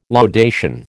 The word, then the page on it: laudation: American English